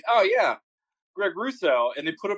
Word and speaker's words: Rousseau: R-O-U-S-S-E-A-U. – Yeah, see, I was going for that ew sound at the end. – You go for that junior sound. – Ew. – I'm sitting here like, R-U-S-S-O, Russo, yeah.